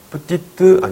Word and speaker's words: petite: 뿌띠, 뜨 아니죠? 예, 뿌띠, 뜨. 살짝 발음돼요. 뿌띠, 뜨. 뿌띠, 뿌띠. 살짝 발음이 되지만 이 발음은 꼭 해주셔야 돼요.